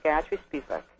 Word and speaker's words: Gayatri Spivak: É isso assim que pronuncia? – Exatamente, Gayatri Spivak.